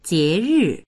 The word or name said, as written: jie2ri4